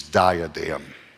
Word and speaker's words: diadem: This is not the Greek word you're probably thinking of for crown, which is diadem. Diadem is the way we would pronounce it.